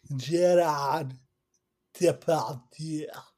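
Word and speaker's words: Gérard Depardieu: But this movie also has LL Cool J, Timothy Hutton, and Gerard Depardieu. – And there go our French listeners. – Gerard Depardieu. – That's actually probably pretty close to how it's pronounced.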